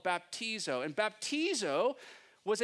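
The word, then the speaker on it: baptizo (βαπτίζω): And so the Greek word to baptize is baptizo, is how you say it, or so how we think we say it. It's baptizo.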